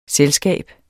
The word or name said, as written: selskab